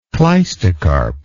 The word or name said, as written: cleistocarp